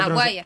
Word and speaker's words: Maguire: Sí, estás hablando de Toby Mac, no sé cómo se Maguire.